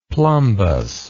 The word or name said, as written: plumbous